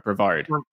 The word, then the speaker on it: Brevard: B-R-E-V-A-R-D? – Yeah, exactly. – Yeah, that would probably be Brevard as opposed to Broward, which is a different county, I suppose.